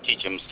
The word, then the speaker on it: Chechens: Doc said that, Seasons? Did I say that correctly? I know I didn't. – Yeah. – Seasons? – Chechens. – Chechens. Chechen rebels.